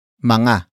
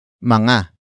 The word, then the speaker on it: mga: But before that, we have Manga. Manga. Manga. Manga is spelled as M-G-A.